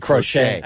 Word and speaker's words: Crochet: Well, I love that nickname, even though they do pronounce it Crochet. – I know. – They should call him Crotchet Rocket.